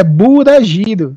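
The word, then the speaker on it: BURAGIRO (ブラジル): Não, é BURAGIRO. Geralmente eles usam uma coisa mais no meio.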